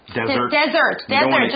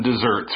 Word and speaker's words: dessert: Desert, 就是沙漠，我们不常吃沙漠。一个 S, okay, 就是 desert, 发音也不同。Let's continue reading. – Okay, yeah, let's talk about some desserts.